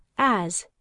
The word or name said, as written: as